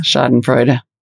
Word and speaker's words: Schadenfreude: Schadenfreude. – Schadenfreude. I literally pronounced that correct. Schadenfreude.